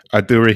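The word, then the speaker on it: Urdu: She conducts therapy in Aduru, is that how you pronounce it? Aduru Hindi, yeah.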